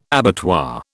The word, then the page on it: abattoir: British English